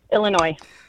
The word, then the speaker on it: Illinois: There are only two state names that end with a silent S. Name one of them. – Illinois.